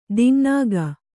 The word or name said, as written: ದಿಙ್ನಾಗ